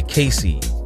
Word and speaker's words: Kaycyy: And closing out that last set was Hold You Up by Casey. Or at least that's how I believe that is pronounced. K-A-Y-C-Y-Y. Close enough. What we're listening to right now is Beryl, B-E-R-R-Y-L, by Pinewood.